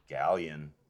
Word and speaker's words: Galeon: And how do you pronounce it? – Galeon. – Galeon. – Like a white person. Like a really white person. – Galeon. I just like laying into that Y, getting it in there.